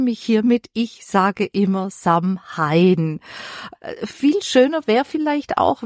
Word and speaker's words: Samhain: Weil es geschrieben ist es ja Samhain, oder? – Ja, genau. Eigentlich wird es Samhain ausgesprochen. Ist ein altes keltisches Wort und bedeutet Ende des Sommers.